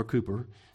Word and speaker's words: Cowper: So I mentioned to you about William Cowper, right? Or Cooper, if you're, pronounce it in the English sense.